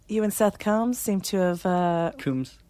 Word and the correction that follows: Coombs: Coombs? Coombs. – Ah. Yeah, no, we all get it wrong. Jeez. Seth Coombs. Please say his name right.